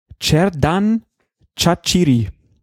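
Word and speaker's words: Xherdan Shaqiri: Äh, auf der letzten Silbe wird betont im Albanischen und deswegen, ähm, ja, heißt der Mann dann Cerdan Chachiri irgendwie.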